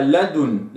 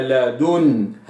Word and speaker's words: لدن: كذلك قوله تبارك وتعالى لدن، من لدن، لا أقرأها لدن، لدن لا، وإنما لدن هكذا.